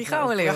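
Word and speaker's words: Gouweleeuw: Ja, Jeffrey Grove Leo. – Prachtig uitgesproken. – Ex-AZ, ex-Heerenveen.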